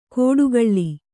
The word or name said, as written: ಕೋಡುಗಳ್ಳಿ